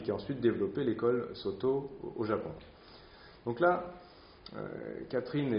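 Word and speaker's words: Sōtō: Et puis là, maintenant, on va focaliser davantage sur une forme du Chan, Shaodong, que nous on prononce Soto, puisque c'est comme ça qu'on le connaît sous sa forme japonaise,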